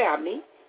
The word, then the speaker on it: Abney: Jeanette Abney, Last name is spelled A, B, N, E, Y, and Jeanette is J-E-A-N-E-T-T-E.